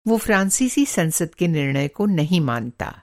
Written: फ़्राँसीसी

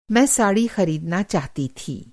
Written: ख़रीदना

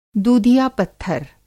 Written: दूधिया-पत्थर

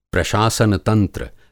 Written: प्रशासन तंत्र

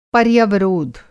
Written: पर्यवरोध

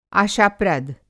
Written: आशाप्रद

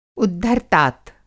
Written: उद्धर्तात

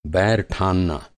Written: बैर

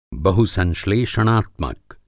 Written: बहुसंश्लेषणात्मक

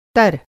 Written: तर

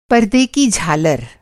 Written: झालर

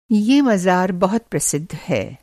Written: मँजार

